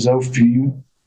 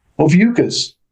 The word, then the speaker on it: Ophiuchus: But yeah, there's poor Ophiuchus, you got this. – Anyway, it hangs out on the ecliptic. There you go. That was Ophiuchus. I can do this. Ophiuchus rhymes with mucus.